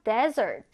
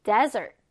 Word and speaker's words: desert: 자, 중요한 건 강세가 요 뒤에 붙어요. 발음교도 보면 강세 표시가 뒤에 돼 있죠. dessert. 후식, 디저트란 뜻이고요. 지금 아래 보면 desert, 사막과 단어 철자가 비슷해요라고 하는데 강세 차이도 잘 알아두셔야 돼요.